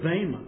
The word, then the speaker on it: bema: The first word is bema, pronounced bema or bematos.